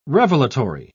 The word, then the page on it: revelatory: British English